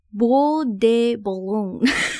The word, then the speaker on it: Bois de Boulogne: Bois de Boulogne.